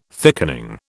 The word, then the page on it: thickening: British English